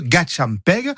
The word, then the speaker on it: gatchampegue: Alors lui, il écrit vraiment comme ça se prononce, G-A-T-C-H-A-M-P-E-G-U-E, Gachampeg.